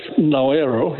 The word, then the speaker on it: Nauru: Yes, Nauru is the original name, and which was anglicized into Nauru. So they want to go back to the original pronunciation, which is significant for them because it's part of where they reconnect with their identity as an indigenous group.